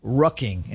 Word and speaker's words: rucking: I said that right, with an R, rucking.